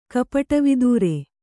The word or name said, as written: ಕಪಟವಿದೂರ